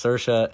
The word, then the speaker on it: Saoirse: Like, reminder, it's pronounced Saoirse, and reminder, Melissa McCarthy was in Gilmore Girls.